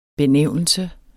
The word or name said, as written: benævnelse